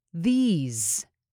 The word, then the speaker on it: These: These. The S is pronounced as a Z. These. These. Business is the same way.